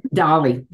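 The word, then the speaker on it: DALL-E: I can't even remember how that's pronounced. Dolly, there you go. I always think of a doll.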